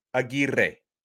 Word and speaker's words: Aguirre: He is of, Dominican ascent, I believe, Dominican or Cuban, and his last name should be pronounced Aguirre, and I'm going to really roll the R's for emphasis, but I heard his coach refer to him as Aguirre.